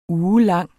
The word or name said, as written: ugelang